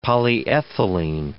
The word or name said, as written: polyethylene